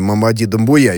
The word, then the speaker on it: Мамади Думбуя: Николай Георгиевич, можно я просто про ударение спрошу? Простите, ради бога, мы сейчас по существу будем говорить, но, может быть, просто придется привыкать к имени Мамади Думбуя, если я правильно ударение расставил. Или поправьте. – Я думаю, ну, ударение-то правильно.